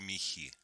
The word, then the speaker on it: мехи: Вот что такое мехи? Вы правильно, кстати, ударение.